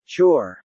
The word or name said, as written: chore